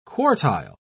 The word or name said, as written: quartile